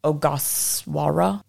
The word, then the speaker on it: Ogasawara: the Ogasaru. No, Ogasawara Islands. Okay, I'm so sorry.